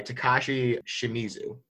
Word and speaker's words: Takashi Shimizu: So this one is written and directed by Takashi Shimizu.